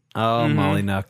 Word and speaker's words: Molyneux: Molyneux. I believe that is the proper pronunciation.